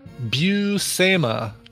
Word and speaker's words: Buscema: Here's the pronunciation. Buscema.